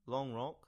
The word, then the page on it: Long Rock: English